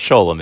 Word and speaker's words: Sholom: Yes, I know the Hebrew word is pronounced Shalom, but in Chicago, you call it Sholom if you know anything about Chicago.